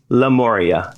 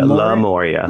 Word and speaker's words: Lemuria: I've heard it Lemuria. Is it? How do you pronounce it? – I pronounce it Lemuria, if I think about it. Lemuria. – Lemuria. – Yeah, not Lemuria. – Right, it wouldn't be Lemuria.